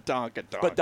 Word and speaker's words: badonkadonk: You know, you got the badunk-da-bunk, badunk-a-bunk, bunk-a-dunk. How do you say it? – Badonkadonk. – Badonkadonk. Sorry.